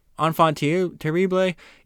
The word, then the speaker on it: enfant terrible: Enfant terrible.